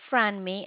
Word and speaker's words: Franmi: My name is Franmi. It's spelled F as in Frank. R-A. Mm-hmm. N-M-I. – M-I. Okay. – Yep. It's Franmi.